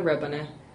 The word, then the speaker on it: Rebane: Kaya Rebane. Spell my last name. R-E-B-A-N-E.